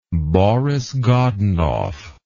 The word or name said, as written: Boris Godunov